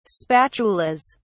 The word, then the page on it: spatulas: British English